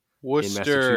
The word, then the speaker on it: Worcester: He was a teacher at the Wooster Boys Trade School. – Worcester, it's Worcester. – Isn't that what I said? – You said Wooster. – Well, it's spelled Worcester.